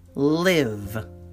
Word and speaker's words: live: Our first homograph is L-I-V-E, live, live. It's a short I sound, live, the verb, you know, to reside at.